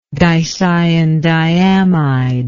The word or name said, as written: dicyandiamide